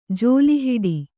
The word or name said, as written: ಝೋಲಿಹೊಡೆ